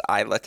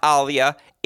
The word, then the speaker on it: Ajla: It has to be Serena Williams versus Ajla Tomljanovic. And little pronunciation lesson it's not Alia, it is Ajla.